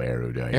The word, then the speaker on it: erudite: You are becoming so erudite. – Erudite. – Hit it. – How do you pronounce it? – No, you said it right. Erudite.